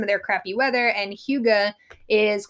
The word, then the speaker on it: hygge: I said hygge, but it's not hygge. – So hygge is spelled H-Y-G-G-E, and it is some Danish kind of keys to happiness.